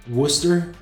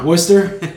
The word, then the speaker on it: Worcester: or I should say Worcester. How do you pronounce it, guys? – You got it right. – Worcester? Worcester, Mass. – You can drop that R if you want to be up there.